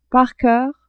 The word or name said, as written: par coeur